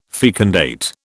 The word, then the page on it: fecundate: British English